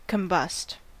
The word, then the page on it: combust: American English